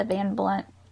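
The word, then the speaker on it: Van Blundht: So I read on the Wikipedia, the X-Files wiki, how Eddie says there's an H, the Van Blunt, the H is silent.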